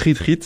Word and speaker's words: Ritrit: Oui, j'ai découvert Retreat, ça se prononce comme une retraite en anglais, mais ça s'écrit tout simplement R-I-T-R-I-T.